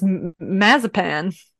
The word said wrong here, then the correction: Mazepin: is the reason why they don't want that. – He might spin. Isn't that how you pronounce it? – Mazepin.